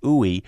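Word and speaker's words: UI: Greg, I've been thinking about a band from the early 90s, mid-90s called OOI. That's spelled U-I, but pronounced OOI, that was part of that scene.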